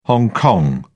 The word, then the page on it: hong kong: British English